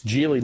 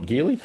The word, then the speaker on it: Geely: So it's Geely, not Geely, even though it's spelled obviously G-E-E-L-Y, but they pronounce it Geely.